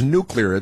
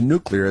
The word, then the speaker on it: nuclear: The word is nuclear. It's an important word. It's nuclear. It's unclear to me why you would pronounce it nuclear when the word is obviously nuclear.